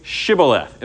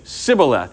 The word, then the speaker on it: Shibboleth: Say Shibboleth. And they would say Sibboleth. And he would say, off with your head, essentially. Okay? So anybody that lied about being from Ephraim, they could not pronounce that name correctly.